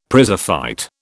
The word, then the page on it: prizefight: British English